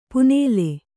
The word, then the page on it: ಪುನೇಲೆ: punēle